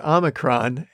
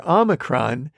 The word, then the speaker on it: Omicron: The O in the biblical Greek letter Omicron is commonly pronounced like the O in the English word not, like what we would call a soft O pronunciation.